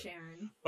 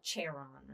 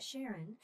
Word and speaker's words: Charon: And it's spelled Charon. Mm-hmm. So you want to say, like, Charon or something. – Charon. Yep. – But Sharon.